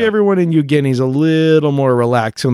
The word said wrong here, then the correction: Eugene: You know, I think, I don't mean to correct you in front of everybody, but I think it's pronounced Eugene. – Oh, shoot. Eugene. My bad.